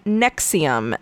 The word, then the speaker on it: NXIVM: And a few years later, they rebranded the program using the name Nexium, and that is spelled N-X-I-V-M because we have to be unique.